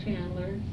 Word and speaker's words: Chandler: My name is Millie Chandler, C-H-A-N-D-L-E-R.